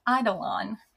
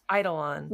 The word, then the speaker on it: Eidolon: And I needed to ask you, how do you pronounce this word? It's Eidolon. – It's Eidolon. – Eidolon.